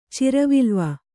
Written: ಚಿರವಿಲ್ವ

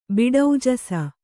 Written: ಬಿಡೌಜಸ

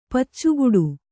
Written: ಪಚ್ಚುಗೂಟ

